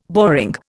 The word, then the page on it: boring: Indian